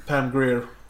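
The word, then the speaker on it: Pam Grier: Eh, övriga skådisar som är nämnvärda är väl Pam Greer. Eh, eller hur uttalar ni det? – Pam Greer.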